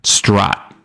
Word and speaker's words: Sderot: It's spelled S-D-E-R-O-T, right? Sderot. Sderot? Sderot. Something like that. Watch what comes up. – Sderot. – So I wasn't too far off.